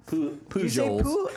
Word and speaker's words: Pujols: Pujols. – How do you say it? – Pujols. – You don't pronounce the L?